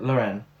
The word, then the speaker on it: Loren: Like Sophia Loren, I'm going to pronounce it. Loren,